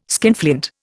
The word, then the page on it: skinflint: Indian